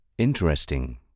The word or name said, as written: interesting